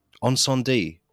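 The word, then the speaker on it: Incendies: Incendie, apparently, is how you pronounce it.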